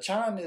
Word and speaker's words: Kyan: Chan is the Okinawan language pronunciation of Kyan.